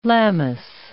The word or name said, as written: Lammas